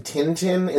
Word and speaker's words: Tintin: I'm pronouncing Tintin in the way that it was meant to be pronounced.